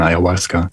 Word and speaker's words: Ayahuasca: Was ist das Ayahuasca und habe ich es richtig ausgesprochen? – Ja, hast du richtig ausgesprochen, Ayahuasca.